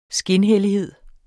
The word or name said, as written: skinhellighed